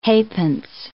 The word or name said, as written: halfpence